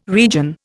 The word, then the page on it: region: Indian